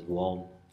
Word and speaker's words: Om: If you take Raja into Karnataka, this state that Mysore is in, central and western South India, and if you take Om into Karnataka, into the Kannada language, you get Vom. They put a semi-vowel. If a word begins with a vowel sound, they'll add a semi-vowel when they don't write it, the semi-vowel, but they pronounce it with the semi-vowel in front. So Om is Vom.